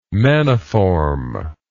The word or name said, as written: maniform